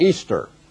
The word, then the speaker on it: Ishtar: I-S-H-T-A-R with a silent H, was pronounced Easter.